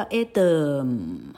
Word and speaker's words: Adam: 哎，等一下哦，atom，atom，老客刚刚念到的是亚当哦，atom 哈，原子跟亚当就差在第一个音节的母音号数不一样而已，亚当念作 atom，atom。又是五号母音 a 哈，Adam，那个 m 又快又急又卷，这样听得到吗？m 哈，Adam，你不会听到 Adam 哈，谢谢你，真是有够有够台客 feel 了哈，哎，都不是那样念哈。好，那，a atom，a t o m，那就原子了，atom，atom，OK。